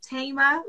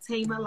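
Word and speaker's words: Thema: Yes, the A is silent. So it's Tema, like Tema Lion.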